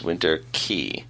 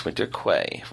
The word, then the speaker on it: Winter Quay: In no particular order, first off is Winter Key. – Yeah. – It's not Winter Key, it's Winter Quay. Rhymes with way, day, stay.